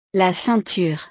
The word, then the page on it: la ceinture: frances